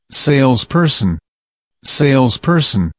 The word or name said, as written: sales person